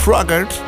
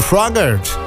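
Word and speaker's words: Froggatt: Voor de input geef ik de Red Balloon van Raymond Fogart. Raymond Froggert, zo mag ik hem op zijn Amerikaans uitspreken. Raymond Froggert.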